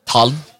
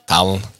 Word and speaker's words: Talon: Och då tar de alla bokstäverna och klämmer dem samman. Så har varit det Talon. – Talon? – Talon. – Okej. Okay. Så nästa gång jag träffar en Talon så ska jag uttala det så.